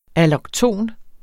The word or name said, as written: allokton